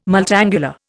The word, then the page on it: multangular: Indian